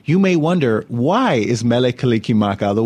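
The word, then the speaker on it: Mele Kalikimaka: The Hawaiian language has no sound for R or S, and every syllable has to end with a vowel sound. So Merry becomes Mele, and Christmas becomes Kalika Maka.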